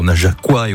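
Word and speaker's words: Najacois: Depuis 20h, on s'est pas mal baladé, même si on a offert un joli coup de projecteur aux Najaquois et aux Najaquoises. Bien comme ça, hein, qu'on prononce.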